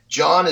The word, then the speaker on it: Jon: John is J-O-N. And my last name is Andersen, but it's A-N-D-E-R-S-E-N.